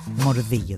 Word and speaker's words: Μορδίγιο: Μορδίγιο δηλαδή, όπως προφέρεται.